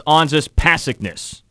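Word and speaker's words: Anžejs Pasečņiks: Number two, Anzis Pasekness. Anzis Pasekness. – You know that sounds... – You know what? Actually, it's Onsis Pasekness. – Oh, well. – Wrong emphasis on the wrong syllable.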